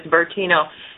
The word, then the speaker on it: Bertino: His last name, first name is John, common spelling, last name is Bertino. B, E as in Edward, R, T as in Tony, I, N as in Nancy, O.